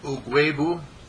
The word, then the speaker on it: Ugwuegbu: Pronounce it clearly and distinctly and tell me what it means. – Ugwebu. – Ugwebu. Ugwebu. – Yes. – U-E-U-G-U-E-B-U. – U-G-W-U-E-G-B-U.